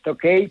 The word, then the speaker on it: Tokei: No, ya lo dices bien, es Tokei porque significa reloj en japonés.